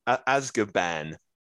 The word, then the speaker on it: Azkaban: Azkaban.